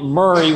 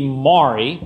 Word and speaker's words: Maury: And despite mispronouncing the name of their county, calling it Murray when it clearly should be Maury, but that's there.